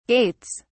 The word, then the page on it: gaits: British English